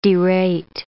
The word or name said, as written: derate